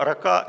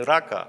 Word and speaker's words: рака: «Кто же скажет брату своему, рака подлежит Синедриону?» Слово «рака», здесь правильно ударение поставлено, не «рака», а «рака».